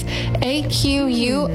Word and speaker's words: Aquileia: It's Aquileia, and it goes into Grotto. – It looks like tequila, but with an A.